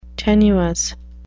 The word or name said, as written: tenuous